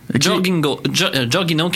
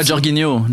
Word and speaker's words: Jorginho: Ornigo en troisième. C'est comme ça se prononce? Jorginho qui joue pour le Chelsea. – Ouais, Jorginho.